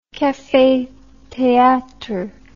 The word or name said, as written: cafe theatre